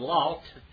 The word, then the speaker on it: Lot: Remember when Abraham and Lot, and by the way, you say Abraham, that's how you say his name in Hebrew. And then Lot is Lot, like Laos, Lot.